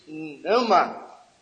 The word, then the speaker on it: pneuma: I'm sure by now that you have learned the truth that the New Testament Greek word for spirit is pneuma, transliterated P-N-E-U-M-A, or pneuma, if you want to pronounce it that way.